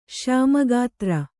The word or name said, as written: ಶ್ಯಾಮಗಾತ್ರ